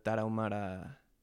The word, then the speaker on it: Tarahumara: It's very interesting because Rarámuris live in the Tarahumara mountain range. The mountain range is called Tarahumara, but their ethnicity is Rarámuri. So it's just a mis... how do I say this? It's just... you don't say Tarahumara as a person. Like, he's not a Tarahumara, he's a Rarámuri.